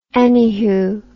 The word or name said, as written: anyhoo